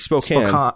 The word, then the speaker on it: Spokane: It's Spokane. – Spokane. – Yeah, we found out it's pronounced Spokane, even though there's an E at the end.